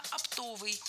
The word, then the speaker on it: оптовый: Оптовый – неверно. Правильно, оптовый. Ударение в этом слове падает на второй слог, на суффикс «ов» – оптовый.